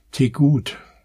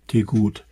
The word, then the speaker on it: Tegut: Es gibt noch einen, und zwar, weiß ich gar nicht, wie der ausgesprochen wird, Tegut. Wie T, aber nur mit einem E und dann gut wie schlecht, Tegut.